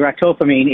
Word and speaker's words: ractopamine: It's banned in China, called ractocamine. What is this stuff, ractocamine? Maybe you can tell us that first. – It's pronounced actually ractopamine.